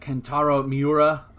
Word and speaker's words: Kentaro Miura: that Kentaro Miura. Uh, I hope I, am pronouncing that right. Uh, basically, the yeah, that sounds right.